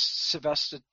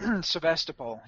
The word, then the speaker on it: Sevastopol: I would say it's Sevastopol. Sevastopol. Yep.